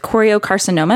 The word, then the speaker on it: choriocarcinoma: We're going to be chatting about Sarah's cancer journey with choriocarcinoma. Am I saying that correctly? – That's right.